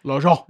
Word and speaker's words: L'Argent: L'Argent.